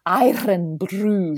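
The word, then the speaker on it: Irn-Bru: Iron Brew. You've got to roll your R's.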